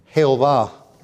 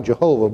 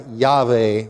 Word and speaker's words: Jehová: And they translated it, they Hispanicized the Tetragrammaton and made it Jehová, as the way you pronounce it in Spanish. Jehovah, its spell looks like Jehovah, but it's pronounced in Spanish, Jehová. And really, if you pronounce the Hebrew letters, it's more like Yahweh or Yahweh.